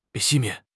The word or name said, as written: 熄灭